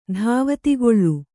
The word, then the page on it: ಧಾವತಿಗೊಳು: dhāvatigoḷu